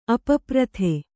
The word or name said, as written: ಅಪಪ್ರಥೆ